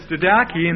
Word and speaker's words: didache: So the first one is didache, and that's spelled D-I-D-A-C-H-E, and actually the final E is a long E.